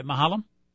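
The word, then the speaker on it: Maholm: That's how you pronounce it, right, Mahalam? – Yep.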